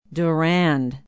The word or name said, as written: durand